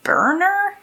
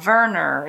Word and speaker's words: Werner: Instead of when I tell people and they think I'm saying Werner. – Oh. – No, Werner. It's spelled with a W, but you pronounce it like it's a V.